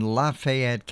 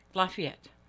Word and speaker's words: Lafayette: The French pronunciation is Lafayette. – Well, they don't pronounce it that way.